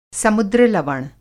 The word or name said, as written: समुद्र-लवण